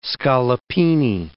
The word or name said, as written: scaloppine